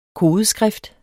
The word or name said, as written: kodeskrift